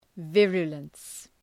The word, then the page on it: virulence: ‘vırjələns